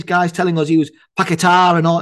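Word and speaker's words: Paquetá: You know, the one thing about the Brazil game that I did value was I watched something on the internet, online, about the players pronouncing the names. Mm-hmm. So Paqueta is Paqueta. He's not Paqueta. He's Paqueta. He pronounced his own name. Went, I am Lucas Paqueta.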